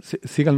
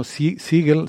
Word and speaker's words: Siegel: Sí, hablaba Ethan Siegel, no, Siegel, siempre lo digo mal, porque creo que me traiciona el actor de las películas de acción.